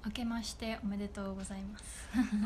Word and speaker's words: Akemashite omedetou gozaimasu: Akemashite omedetou gozaimasu.